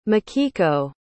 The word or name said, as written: makiko